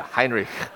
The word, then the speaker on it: Heinrich: And here he is, he's on his deathbed, and then someone asks Heinrich Heine, these German names, okay. And I actually took German, okay, but yeah, Heinrich, okay. There we go, that's how you're really supposed to pronounce it.